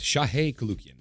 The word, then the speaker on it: Shahe Koulloukian: Yes, Shahe Koulloukian.